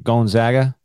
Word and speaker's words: Gonzaga: They put a real emphasis on the gon, G-O-N. – Yeah, like Gonzaga.